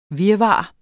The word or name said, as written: virvar